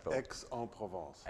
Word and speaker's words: Aix-en-Provence: Aix-en-Provence.